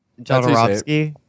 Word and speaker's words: Jodorowsky: I gave them a copy of Sante Segre on VHS, the Jordanovsky. How do you say it? – Jodorowsky? – Yeah, whatever the hell.